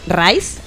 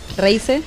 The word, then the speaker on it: Reise: Eh, esta canción es el título del primer sencillo del álbum Rise. Reise.